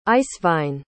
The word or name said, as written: Eiswein